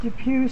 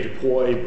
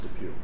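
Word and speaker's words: DePuy: Depew Spine, you'll have to correct my pronunciation, and against Medtronic Sophomore. – Depew is exactly right. – Depew is right. – Looks like you'd say Depoy, but I'm told by the client it's Depew.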